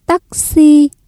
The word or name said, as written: tắc xi